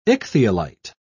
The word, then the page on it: ichthyolite: British English